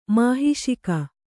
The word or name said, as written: ಮಹಿಷಿಕ